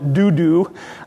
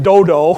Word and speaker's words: Dodo: And then in verse 9, we read about a man named Eleazar, the son of, Brother Chuck pronounced it Dudu. I'm going to pronounce it Dodo. I'm going to make a long O instead of a short O.